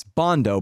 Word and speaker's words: Bandow: And, well, it's Bondo, but it's spelled like Bandow, I guess.